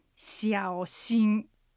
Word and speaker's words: 小心: で発音はひらがなであえて書くとするとしあおしんですね、うん、そうですね。 – でアクセントはしあおが下がって上がる三声、しんが一声、三声一声です。